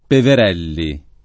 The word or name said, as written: Peverelli